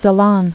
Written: Ceylonese

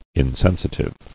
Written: Insensitive

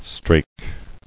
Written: Strake

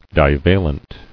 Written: Divalent

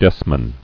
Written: Desman

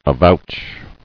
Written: Avouched

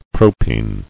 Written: Propene

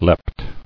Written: Leapt

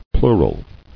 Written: Plurally

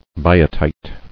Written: Biotite

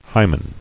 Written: Hymen

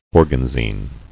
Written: Organzine

